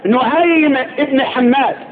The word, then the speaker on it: نعيم بن حماد: نعيم بن حماد هكذا النطق,